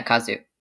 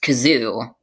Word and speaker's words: Kazu: Yeah, Kazu. And not to be pronounced Kazoo, like the Scottish girl who I'm forgetting her name.